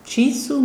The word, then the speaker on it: Chisu: Wir hören jetzt, gut, Sie können ja perfekt Finnisch, Sie können es korrigieren, wenn ich es falsch ausspreche, Chisu mit Munkoti Eo Tella.